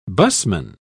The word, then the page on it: busman: British English